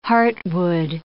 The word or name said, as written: heartwood